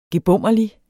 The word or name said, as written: gebommerlig